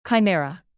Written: chimera

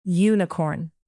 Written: unicorn

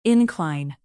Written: incline

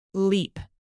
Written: leap

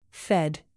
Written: fed

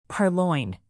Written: purloin

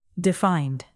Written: defined

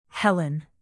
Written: helen

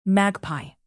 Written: magpie

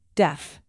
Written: deaf

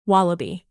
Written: wallaby

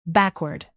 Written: backward

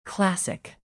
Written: classic

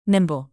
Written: nimble

